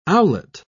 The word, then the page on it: owlet: British English